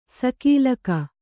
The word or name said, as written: ಸಕಾಲಿಕ